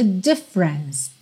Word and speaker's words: difference: A sílaba tônica é a primeira, e tanto o é do meio quanto o é final não são pronunciados. Então você vai pensar nela como tendo dois ataques de som: difference, difference.